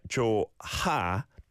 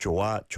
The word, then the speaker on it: 좋아: Yeah, so with the 히읗받침, you may think, oh, is that 좋아? No, you don't say it like that. Just 좋아, 좋아, 좋아, 좋아.